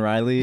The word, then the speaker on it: Riley: Does his last name rhyme with Schmarball? – No, it rhymes with Hiley. It's Lincoln Riley is one, the Oklahoma coach.